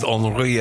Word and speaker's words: Henri: It's spelt Henry in the French way, so I'm going with Henri Herbert.